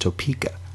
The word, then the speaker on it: Topeka: I think it's pronounced Topeka. – Is it Topeka? – Yeah.